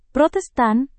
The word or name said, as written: protestan